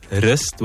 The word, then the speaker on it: wrist: The problem is you're saying wrist with the W. The silent W.